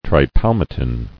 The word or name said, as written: Tripalmitin